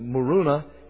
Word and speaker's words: Muruna: And then they said there was a fourth reason, and I'll pronounce it Muruna, M-U-R-U-N-A.